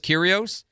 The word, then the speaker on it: Kyrgios: Oh, apparently I was mispronouncing Kyrgios' name. It's just Kyrios.